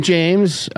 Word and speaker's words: James: I'm James.